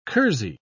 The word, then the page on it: kersey: British English